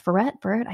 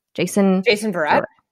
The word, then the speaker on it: Verrett: Because like Jason Verrettana, it's Verrett. I never say his name right. Jason Verrett.